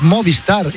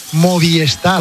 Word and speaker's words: Movistar: Seis, y hay mucha gente que pronuncia mal Movistar. Es Movistar. Y la gente dice Movistar.